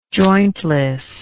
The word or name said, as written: jointless